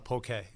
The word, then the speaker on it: poke: And is it pronounced poke? I've heard it poke. What do you think? – It's definitely pronounced poke.